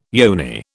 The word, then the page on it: yoni: British English